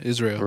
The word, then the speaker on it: Israel: Because I call you Israel. I know that's not how you say it. – It is how you say it. Israel. Well, Israel, right? – Yeah, Israel.